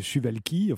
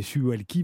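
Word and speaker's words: Suwałki: Oui, la Russie pourrait vouloir s'emparer du couloir de Suwalki. Enfin, vous prononcez Suwalki, vous avez sûrement raison.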